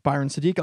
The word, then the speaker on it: Byron Sadik: Give us a good review if you get a second on iTunes, 5 stars, and you can follow Byron Sadik online at Byron Sadik, Facebook, Instagram, Twitter, BYRONSADIK. It's spelled like Byron's a dick, but without the C.